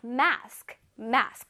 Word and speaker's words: mask: Alright, mask, mask. 마스크요. mask 이렇게 한 친구들이 있죠. 근데 mask, mask가 아니라 ma, ma, ma, mask, mask.